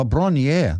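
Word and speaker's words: Bron-Yr-Aur: So Bronior is Welsh for Hill of Gold. Oh, pronounce it here, Bronier. Oh, Bronier, there we go.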